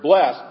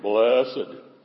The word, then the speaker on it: blessed: we make it a two-syllable word, blessed. Isn't that the way we kind of say it? But actually, the way to pronounce it would be blessed, because how many of you got up this morning and say, my hair is messed because I didn't have time to get dressed?